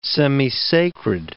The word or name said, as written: semisacred